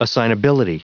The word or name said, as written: assignability